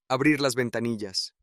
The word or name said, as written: abrir las ventanillas